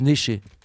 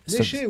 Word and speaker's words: Neschers: ça s'écrit Neschers, mais ça se prononce Neschers. – Néché, oui, bien sûr.